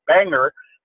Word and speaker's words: Bangor: And Maine for several years now, they play in downtown Bangor, as the locals would pronounce it, at the Bangor Civic Center.